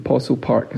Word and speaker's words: Possil Park: Uh, Possil Park is spelled like fossil with a P.